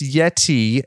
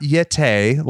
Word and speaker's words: Yeti: It's actually pronounced Yeti and not Yete, like Tony Schiavone said.